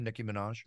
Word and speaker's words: Minaj: Nicki Minaj, is that how you say her name? Nicki Minaj? – Yep.